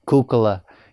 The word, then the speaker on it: Kukkola: D-A-V-I-D. Okay, that's the easy part. Kukla. K-U-K-K-O-L-A.